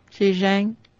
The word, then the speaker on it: Zhejiang: Zhejiang, am I saying that right? – I think so. – Zhejiang,